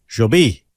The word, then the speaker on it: Jobim: So you heard those on Banjo Beam, getting its name from her inspiration, Antonio Carlos Tom Jobim. The M is silent.